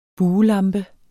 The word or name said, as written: BUELAMPE